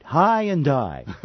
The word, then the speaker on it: Hyundai: See, now you guys pronounce it right. High and die.